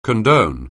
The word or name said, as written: condone